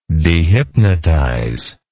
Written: dehypnotize

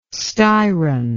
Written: Styron

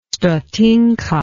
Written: stotinka

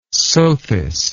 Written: sothis

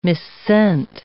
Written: missent

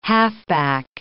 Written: halfback

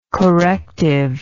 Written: corrective

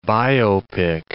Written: biopic

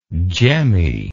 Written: gemminess

